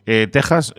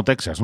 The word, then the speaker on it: Texas: Texas, no Texas, ¿no? Esto es como México, se escribe con X, pero se pronuncia con J por aquella, bueno, de mantener aquella escritura.